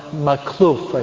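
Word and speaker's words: Makhlouf: Okay, Saint Charbel Maclouf, I think is the way you pronounce his last name. Maclouf.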